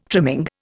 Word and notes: trimming: Indian

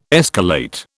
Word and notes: escalate: British English

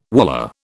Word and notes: wallah: British English

